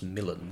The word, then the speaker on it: Milan: In the play, it's supposed to be pronounced Milan. This was drummed into us right at the beginning of the performance I did, but anyway.